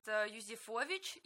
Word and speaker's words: Юзефович: В этом году будет текст писать Леонид Юзефович. Если я не ошибаюсь, ударение у него фамилия ставится так, но слышала и другие тоже произношения.